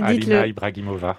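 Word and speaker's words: Ibragimova: Bien prononcé. – Vas-y, dites-le. – Alina Ibrahimova. – Oui, c'est bien dit.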